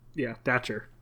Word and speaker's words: Dacher: So he actually, you mentioned, like you said, one of his students, Dacher Keltner. I hope I'm saying his name correctly. – Yeah, that's what, Dacher.